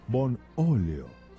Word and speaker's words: Bonolio: Bonolio. Con acento en la O.